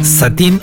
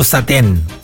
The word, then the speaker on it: satén: Satín o satén. Ambas son correctas.